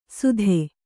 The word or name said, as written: ಸುಧೆ